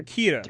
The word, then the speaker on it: Ciara: Like C-I-A-R-A. Like, I want to, like, credit the girl who did the Twitter Kira. – Thread, but how is it? Kira? – Kira, yeah.